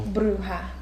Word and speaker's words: bruja: Bruja. – Bruja.